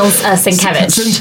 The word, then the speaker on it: Sienkiewicz: Bill Sienkiewicz. – Sienkiewicz.